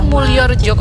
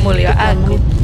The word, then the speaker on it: Mulyorejo: Oh, Mulyorejo Kota Malang maksudnya merambat. Ya. Saya tadi bacanya Mulyo aku.